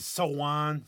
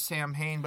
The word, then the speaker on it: Samhain: Some people pronounce it Sowan, Samhain. English people pronounce it Sowan. – Sowan, or I was thought it was like Samhain. Yeah, Donald Pleasance in the second original Halloween, he said it was Samhain, but that's not how it's pronounced.